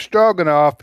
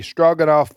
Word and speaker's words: Stroganoff: It's spelled, the Russian spelling is Strogonov. – Stroganoff. – But the English, we do it Strogonoff, which is phonic, stroganoff. But it's Russian.